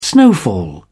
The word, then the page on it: snowfall: British English